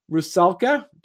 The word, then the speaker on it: Rusalka: She's here to talk to us about her latest Kickstarter project, Rusalka. Did I say that right? – Yeah.